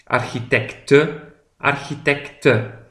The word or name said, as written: de architecte